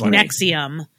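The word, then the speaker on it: NXIVM: It's N-X-I-V-M, and they actually did that on purpose to make it hard to search on the internet. – Oh, really? – It's pronounced Nixium.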